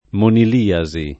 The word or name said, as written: moniliasi